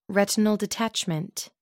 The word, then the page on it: retinal detachment: American English